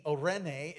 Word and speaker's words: Eirene: The Greek perspective is, let me spell it for you, the transliteration in English, E-I-R-E-N-E, Irene.